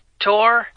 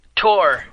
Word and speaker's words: Tor: My name's Tor. – Achula. – No, Tor. – Oh, wait. – Wow. You have to spell it. – T-O-R-E-R-J-E-E. Just T-O-R, Tor.